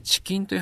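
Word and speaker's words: 遅筋: チキンという話をしました。遅い筋肉と書いてチキンです。で、このチキンというのは、二次性サルコペニアで萎縮しやすいとも言われています。チキン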